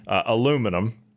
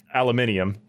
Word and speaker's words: aluminium: aluminum, aluminium, excuse me, for our UK listeners. I do know the difference. Yeah, it is spelled different. I do know the difference in pronunciation, so I'm not doing it to spite you.